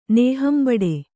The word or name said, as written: ನೇಹಂಬಡೆ